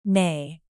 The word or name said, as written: neigh